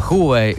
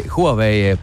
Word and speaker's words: Huawei: Vēl joprojām pie mums ciemos šovakar ir eh, Mikus Stillers, un te ir pareizi tagad jāizrunā uh, Huawei, Huawei produktu apmācību, vadītājs.